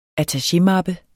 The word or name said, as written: attachémappe